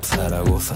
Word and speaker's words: Zaragoza: Zaragoza, say it right, Zaragoza.